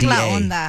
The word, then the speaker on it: La Onda: Am I saying that correctly? – I don't think you are. I don't know what you're trying to say. – O-N-D-A. – It's like La Onda. La Onda, yeah.